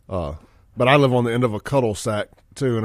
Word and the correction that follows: cul-de-sac: It's a cul-de-sac? – Yeah.